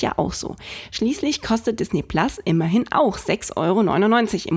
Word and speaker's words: Disney Plus: Ja, es heißt Disney Plus und nicht Plus, man schreibt es mit einem schönen Pluszeichen hinter dem Namen, weil es auf Englisch ausgesprochen einfach auch noch viel cooler ist.